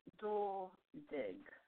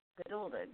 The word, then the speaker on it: Geduldig: Geduldig. Geduldig. Just the way it's spelled. – Geduldig. – Geduldig. – That's my heart. – Yeah, there's no extra consonants in there, no N. People always want to put an N in there.